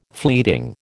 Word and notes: fleeting: American English

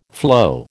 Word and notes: flow: American English

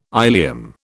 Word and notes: ileum: British English

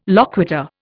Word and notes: loquitur: Indian

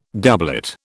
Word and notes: doublet: British English

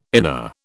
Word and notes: inner: British English

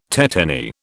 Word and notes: tetany: British English

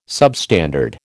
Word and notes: substandard: American English

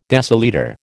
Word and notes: decilitre: American English

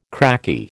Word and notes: cracky: American English

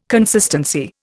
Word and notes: consistency: Indian

